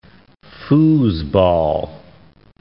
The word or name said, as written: foosball